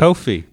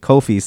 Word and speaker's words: Kofi: Then he asks his granddad, like, what did you know about Coffee? And he was like... – Kofi. – Kofi, sorry. But it's all good.